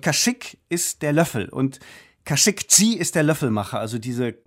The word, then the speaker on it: Khashoggi: Das ist Ihnen sehr wichtig, Khashoggi. Wir sagen hier oft Khashoggi, das ist aber nicht richtig.